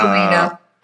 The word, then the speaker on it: Bellino: Bellino.